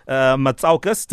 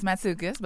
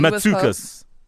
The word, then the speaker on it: Matsoukas: Her name is Melina Matsoukas. Did I pronounce that correctly? – It's Matsoukas. But Matsoukas.